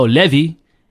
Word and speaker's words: Levy: So Levy, the English way, it's L-E-V-Y. Sekgapane is S-E-K-G-A-P-A-N-E.